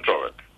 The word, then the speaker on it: čovek: A što se tiče ovoga, ovaj gospodin, pre ovaj gospodin podsjetio dosta na ovaj primjer tog imenice čovek. Znači, mi smo učili da se akcentuje ovako. Čovek, čoveka, čoveku, čoveka, čoveče, s čovekom i o čoveku. A sad je to promenjeno.